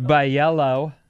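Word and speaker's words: Bijelo: Bajello Dugme, pronounced Bajello.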